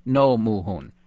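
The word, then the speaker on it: Roh Moo-hyun: I'll just tell our listeners that they'll be a little confused when they see his name because it's spelled R-O-H, not N-O-H, but it's pronounced No Moo-hoon.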